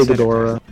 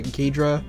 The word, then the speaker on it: Ghidorah: I say Ghidorah. – That's how you say it. Whoever says it Ghidra, Oh, psh, so wrong.